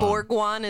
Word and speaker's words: Bourgoin: Definitely not Bourgoin. – Bourgoin. – Bourgeois. – Bourgoin is definitely the appropriate word.